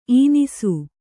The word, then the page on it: ಈನಿಸು: īnisu